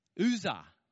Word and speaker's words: Uzzah: Uzzah.